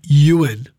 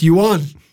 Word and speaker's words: Ewan: Yeah, no, it's Ewan, but you might know me, people who really deep down know me, my name's actually Ewan, which is E-W-A-N, which is a Welsh name. But if you pronounce it in Welsh, it's Ewan.